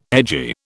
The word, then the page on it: edgy: British English